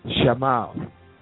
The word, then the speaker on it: Shammah: That's S-H-A-M-M-A-H, and it's pronounced Shammah.